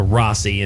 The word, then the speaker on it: Rhossi: Well, it's spelled R-H-O-S-S-I, so I could have, you know, the vowel and two consonants in between another vowel. I should have probably shortened that out to Rossi and not made Rossi. – A long O, yeah.